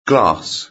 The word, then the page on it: glass: British English